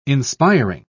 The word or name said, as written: inspiring